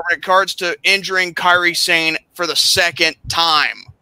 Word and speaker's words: Kairi Sane: Nia Jax versus Carly Sane. Now, have I got that name right? – I tell you this, it's pronounced Kairi Sane.